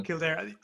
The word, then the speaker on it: Kildare: castle in Kildare. Am I pronouncing that properly? – Kildare, yes. – Sort of, close enough.